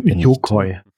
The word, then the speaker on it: Yokoi: Yoko. Vergebt mir, wenn ich Namen falsch ausspreche. Ich bin Yokoi. – Was habe ich gesagt? – Yoko hast du gesagt.